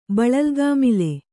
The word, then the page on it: ಬಳಲ್ಗಾಮಿಲೆ: kannada